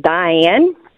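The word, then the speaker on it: Dian: Everyone would always pronounce my first name wrong because it's Diane, but it's spelt differently.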